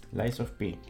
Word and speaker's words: Lies of P: Se trata de Lies of P. ¿Lo he dicho bien? – Lies of P.